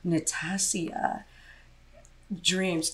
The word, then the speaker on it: Natassia Dreams: Today I'm doing part of my Pride series and I have the beautiful Natassia Dreams.